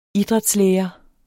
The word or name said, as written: idrætslærer